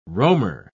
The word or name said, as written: roamer